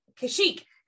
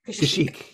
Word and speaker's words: Kashyyyk: Kashyyyk. – Kashyyyk, yes.